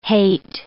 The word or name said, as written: haet